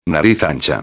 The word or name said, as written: ancha